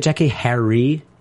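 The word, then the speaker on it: Jackée Harry: But isn't it Jackie Harry? See, I thought we were always calling her Jackie Harris, and it's Jackie Harry.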